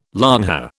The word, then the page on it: longhair: British English